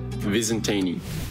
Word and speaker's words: Visentini: Some people seem to have a bit of a tough time with my first and last name, so it's pronounced Dante Visentini. – Dante Visentini.